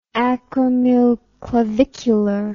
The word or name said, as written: acromioclavicular